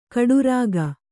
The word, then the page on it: ಕೊಠಾರಿಕೆ: koṭhārike